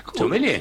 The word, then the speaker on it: sommelier: Sin la R. No se pronuncia la R final. Nosotros aquí decimos sommelier.